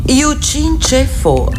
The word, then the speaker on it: Yu Chin Che Fo: Yu Chin Che Fo, accento sul Chin e sul Fo.